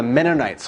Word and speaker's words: Mennonites: Then they're like, no, let's call them the Mennonites. So it's actually a curse word. So how you pronounce it is like Menno, like a Menno, like a Menno. Menno, night, like night as in nighttime.